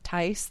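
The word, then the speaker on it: Thijs: Qualin in the chat channel says that the name that we were mangling, the this with the J, is usually pronounced Tice, like dice. The IJ is Dutch and makes an AI sound.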